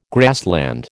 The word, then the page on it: grassland: American English